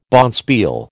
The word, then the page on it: bonspiel: American English